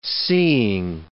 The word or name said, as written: seeing